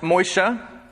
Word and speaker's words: Moses: Moses, his name in the original language is pronounced Moisha.